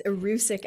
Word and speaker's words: erucic: It's erucic acid or uric acid? I think it's erucic. You're right. I actually had realized I had been pronouncing it wrong. Yes, it's erucic acid.